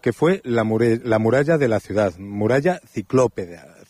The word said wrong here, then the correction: ciclópea: ciclópeda. Creo que lo he dicho bien, no lo sé. – Sí, es la muralla ciclópea ya del siglo IV,